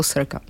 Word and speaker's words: Circa: Cirka. Jsem si teda říkala, že Češi tomu možná říkají Cirka, přes to píše Circa. Ale čte se to Cirka, anglicky.